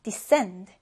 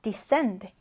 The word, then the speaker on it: descend: 자, 이 단어는 발음 주의해 주세요. 이때 s 발음을 해주시면 돼요. 그래서 descend, descend 이렇게 발음해 주는데요.